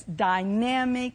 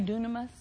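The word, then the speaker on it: dynami (δύναμις): And I love how that says dynamic in its working. That word dynamic in the Greek is dynami. I've been told that's how you say it. We usually say dunamis, Greek word.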